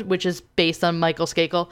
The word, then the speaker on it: Skakel: Skakel is how you say his last name, right? – Skakel, yes.